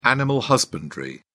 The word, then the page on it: animal husbandry: British English